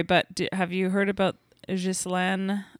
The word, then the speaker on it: Ghislaine: We always say Ghislaine in I know. – French, ugh. But she pronounces it Ghislaine Maxwell. – Maybe because she's from the UK.